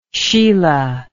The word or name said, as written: Sheelah